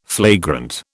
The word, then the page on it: flagrant: British English